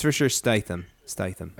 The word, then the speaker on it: Statham: Real quick, I want to hear you guys do your best impression of Jason Statham introducing himself.